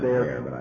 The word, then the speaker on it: Bexar: Not in Bayer County, only in Dallas County. – B-E-X-A-R. – Right. It's pronounced there.